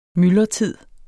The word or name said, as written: myldretid